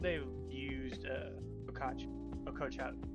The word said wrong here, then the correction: Ochako: Can't say her name right. – Ochako.